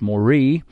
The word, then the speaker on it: Moreh: The plain of, and you might think that's Moriah, but it's not. It's Moreh, M-O-R-E-H, and the Canaanites was then in the land.